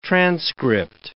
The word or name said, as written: transcript